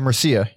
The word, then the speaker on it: Murcia: But yeah, so they started it, and then they recruited Johnny Thunders, the drummer they started with, Billy Merica or Merca. – Wait, how do you spell it? – M-U-R-C-I-A. – Oh, and Mercia, yeah.